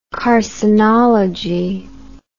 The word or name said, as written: carcinology